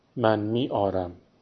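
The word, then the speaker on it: می‌آورم: To bring, آوردن, من می آورم. Pronounced من می آرم.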